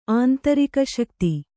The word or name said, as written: ಆಂತರಿಕಶಕ್ತಿ